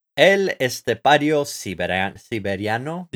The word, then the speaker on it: El Estepario Siberiano: I'm going to butcher his name. El Estepado Cebriano. Did I say his name right? – El Estepario Siberiano, maybe? Yeah. I'm saying it like in a Spanish accent.